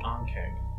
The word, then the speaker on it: Ankheg: How did you pronounce that monster name again? Ankeg? – Ankeg. A-N-K-H-E-G. – I think I may have read about these creatures somewhere.